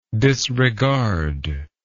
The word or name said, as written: disregarder